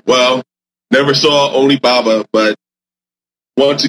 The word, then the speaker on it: Onibaba: As far as I know, it's called Onibaba.